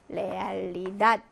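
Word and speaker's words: realidad: 자, 얘도 역시 그 밖에 자음으로 끝났네요. 그래서 레알리닷, 레알리닷이라고 읽어주시면 됩니다.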